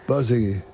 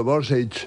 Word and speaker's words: Borzage: Nunca se sabe, cada uno lo dice de una manera. Borzage. La pronunciación. – No, es lo que ha dicho, Borsage. – Borsage. – Pero no. – No, Borsegui. – Yo había entendido Borsegui. – Borsegui, yo como tú, Borsegui. – Yo había oído en alguna entrevista como Borsegui.